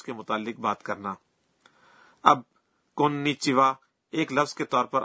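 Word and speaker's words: کونیچیوا (こんにちは): کونیچیوا اس میں بھی آخری وا کے متعلق یہی کہا جا سکتا ہے. اس کا تلفظ وا ہے لیکن اس کو لکھنے کا انداز ہا ہے.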